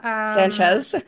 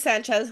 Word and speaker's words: Sanchez: Lauren Schnatz also. – Sanchez. – Okay, I just killed her name. I'm sorry, I butchered her name completely.